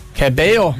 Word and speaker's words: Cabello: Cabello. She's the Spanish pronunciation.